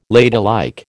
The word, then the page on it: ladylike: American English